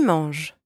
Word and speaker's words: manges: Tu manges. You eat. Tu manges. And don't pronounce the S at the end.